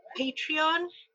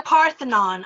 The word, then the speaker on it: Patreon: They also have a Patreon. Am I saying it right? I always say Parthenon. I do not know why, but yes, they have a Patreon, and you could also tip like a few dollars here and there.